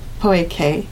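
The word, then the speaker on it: pojke: That's P-O-J-K-E, pronounced Poeke.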